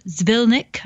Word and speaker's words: Zvilnik: There's a Zvilnik.